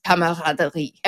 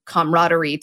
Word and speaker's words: camaraderie: Like we use the word camaraderie and it is spelled the way the French word is, camaraderie. Every single one of those syllables has the same thing. Whereas we've almost misinterpreted the word camaraderie to sound like it has the Latin C-O-M syllable at the beginning of it. And sometimes you see that as variations in dictionaries now because people assumed it meant like doing something together.